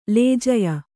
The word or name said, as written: ಲೇಜಯ